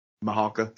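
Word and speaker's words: Mihalka: Mahalka. Yeah. It's Mahalka.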